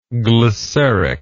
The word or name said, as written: glyceric